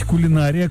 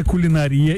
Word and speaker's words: кулинария: Как правильно говорить, кулинария, кулинария или допустимо оба варианта? – Допустимо оба варианта.